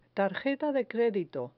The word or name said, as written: tarjeta de credito